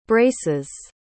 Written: braces

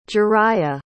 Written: jeriah

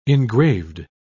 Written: engraved

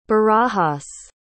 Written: barajas

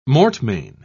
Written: mortmain

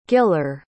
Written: giller

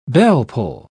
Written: bellpull

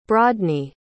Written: brodny

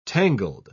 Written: tangled

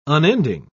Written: unending

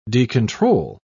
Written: decontrol

